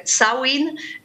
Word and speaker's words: Samhain: Z Markiem, z ojcem Patrycji i Wiktora, dodajmy, bo my założyliśmy jako małżeństwo zespół Samhain, który czyta się Sawin.